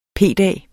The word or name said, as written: p-dag